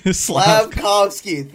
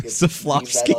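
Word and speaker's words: Slafkovský: I've already come to terms with us getting Logan Cooley instead, or Slavkovsky. I can't say his name properly. – Slavkovsky.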